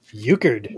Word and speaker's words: Euchred: This is a Euchred American India Pale Ale. Brewed and canned in Chicago, Illinois. – Euchred? – Euchred. Like the game? Yeah.